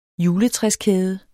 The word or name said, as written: juletræskæde